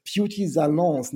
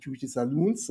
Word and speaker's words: Beauty Salons: Beauty Salons, nicht Beauty Saloons, sondern Beauty Salons.